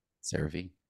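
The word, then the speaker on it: CeraVe: And then it's kind of funny, like Michael Cera. So you kind of remember the name, Cera V. And you pronounce it correctly.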